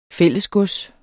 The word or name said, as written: fællesgods